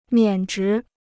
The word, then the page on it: 免职: miǎn zhí